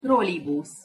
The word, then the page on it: trolibusz: Hungarian